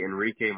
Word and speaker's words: Enrique: The first, it's Jose Enrique Martinez, so my full name. – And that's E-N-I, whoops, E-N-R-I-Q-U-E.